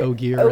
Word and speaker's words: Ogier: It's very close to ogre, right? Like right. – Ogier, and that's the thing he knows, yeah.